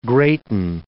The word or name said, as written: greaten